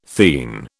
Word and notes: theine: British English

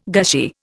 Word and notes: gushy: Indian

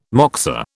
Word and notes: moksa: British English

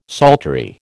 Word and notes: psaltery: American English

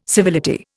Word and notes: civility: Indian

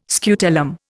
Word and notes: scutellum: Indian

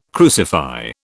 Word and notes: crucify: British English

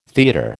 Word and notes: theatre: American English

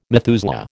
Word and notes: Methuselah: American English

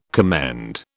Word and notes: commend: British English

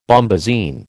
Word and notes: bombasine: American English

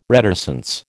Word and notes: reticence: American English

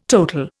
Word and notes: total: Indian

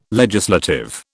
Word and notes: legislative: British English

